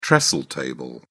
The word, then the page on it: trestle table: British English